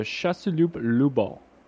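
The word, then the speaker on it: Chasseloup-Laubat: I want to talk about Gaston de Casalou-Lambeau. Is that how you pronounce that? – Gaston de Chasseloup-Lubal.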